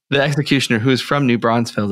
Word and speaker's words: New Braunfels: Well, you lived in New Brunsville. – Okay. You said it wrong. It's New Braunfels.